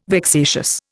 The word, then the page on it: vexatious: Indian